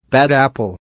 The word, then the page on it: bad apple: American English